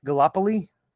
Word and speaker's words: Gallipoli: Some of his most notable films include Gallipoli.